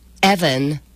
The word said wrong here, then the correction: Even: Even Ormistead. – Even. – Even Ormistead.